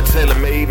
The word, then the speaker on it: TaylrMayd: It's your boy TaylrMayd. Make sure you spell it right. T-A-Y-L-R-M-A-Y-D.